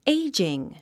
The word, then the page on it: aging: éidʒiŋ; エイジン